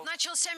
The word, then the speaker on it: начался: Ну, во-первых, в слове «начался» ударение падает на третий слог. «Начался». И, кстати, некоторые словари дают второй допустимый вариант «начался», но на первый слог не ставим ударение. Первый слог под запретом.